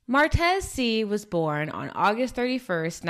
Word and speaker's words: Seay: So Martez, his last name, it's pronounced Seay, but it's spelled S-E-A-Y, just so you guys have a visual for that.